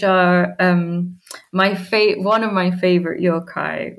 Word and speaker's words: yokai: And added a lot of lovely lore about the Japanese yokai. I believe I'm saying that right, yokai? – Yokai.